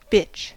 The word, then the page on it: bitch: American English